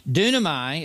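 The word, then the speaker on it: Dunamai (δύναμαι): Dunamai is the Greek word. Dynamite is the English word.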